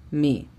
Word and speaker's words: me: La pronunciación fonética de me sería M-I, me.